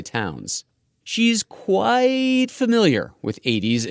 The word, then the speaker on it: Aedes: That's two Latin words, Aedes, A-E-D-E-S, and aegypti, spelled like A, Egypt, I.